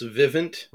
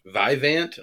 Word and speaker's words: Vivint: There's a little bit of a miscommunication on how you pronounce Vivint. For the longest time, we read it as Vivant almost, or something like that.